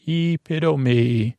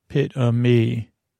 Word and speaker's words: epitome: Pit of me. Um, one reason is similar to Italian. All the vowels from Greek words are pronounced. No silent e's here.